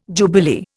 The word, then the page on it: jubilee: Indian